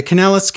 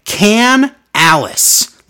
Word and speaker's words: Kanellis: Maria Kanellis. Kanellis with an E-L. Cole keeps saying Can-Alice. Like, Kanellis actually clean the kitchen at the Brady house? All night. Can-Alice. Since when? He's never pronounced it like that.